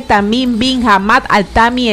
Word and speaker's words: Tamim Bin Hamad Al Thani: Ay, no me entendiste, que es Su Alteza Jeque Tamim Bin Hamad Al Thani Emir del Qatar.